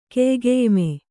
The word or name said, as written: ಕೆಯ್ಗೆಯ್ಮೆ